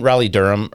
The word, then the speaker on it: Raleigh-Durham: Isn't Raleigh Durham, or isn't that kind of, am I saying that wrong, or...